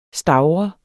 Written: STAVRET

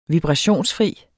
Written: vibrationsfri

